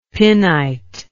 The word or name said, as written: pinite